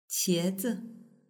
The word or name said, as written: qiezi